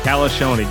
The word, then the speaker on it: Calascione: Calascione.